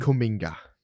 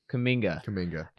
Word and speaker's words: Kuminga: Jonathan Kuminga. – Kuminga.